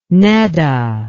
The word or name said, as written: nedda